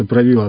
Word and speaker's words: Правило: И там прямо с первых страниц в этой книге было написано о том, как русский воин занимается на тренажере, который назывался «Правило», от слова «править», ударение на букву «и».